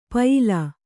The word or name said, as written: ಪ್ಯಾಲೆ